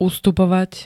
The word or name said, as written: ustupovať